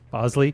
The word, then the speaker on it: Bosley: Is it Bosley or Bosley? – Bosley.